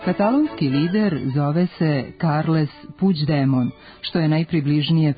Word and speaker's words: Puigdemont: G u kombinaciji glasova IGD od izvornog Puigdemont, kako se na katalonskom piše ovo prezime, izgovara se približno našem Đ, ispred kojeg postoji prizvuk glasa I, koji se nedovoljno jasno čuje i lepi se na afrikatu, pa ga nije potrebno prenositi, a D još uvek nije postalo sporno.